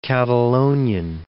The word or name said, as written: Catalonian